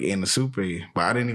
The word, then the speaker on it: Anisupe: So I had to keep asking him, and then he was like, it's, pronounced Anna Super.